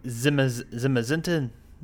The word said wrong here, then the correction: Zamazenta: No, that's yours. That's the shield Pokemon. Zamazenta is yours. – Zamazenta.